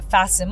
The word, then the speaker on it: fasten: それから fasten 閉めるあの fasten your seat belt シートベルトを閉めてくださいという場合の fasten も t を発音しない、